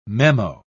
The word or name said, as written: memo